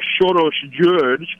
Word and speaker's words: Soros György: His Hungarian name is actually Soros György. That's how you pronounce it in Hungarian.